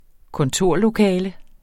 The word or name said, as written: kontorlokale